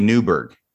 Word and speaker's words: Neibaur: Is that how it's pronounced? Nyberg? Is it Newberg or Nyberg? Yeah. Well, this would be Newberg. I think yeah.